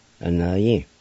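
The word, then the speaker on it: Anvyi: A na yi.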